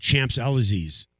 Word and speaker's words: Champs-Élysées: Champs-Élysées? Is that how you pronounce it? – Champs-Élysées? – Champs-Élysées? – Yes. I'm going to go shopping on the Champs-Élysées.